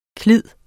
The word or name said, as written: klid